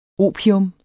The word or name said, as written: opium